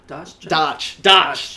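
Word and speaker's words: D'Arce: The first, we've done the first, the Dasach choke. I can never pronounce that. – Darch. – That's how we say it.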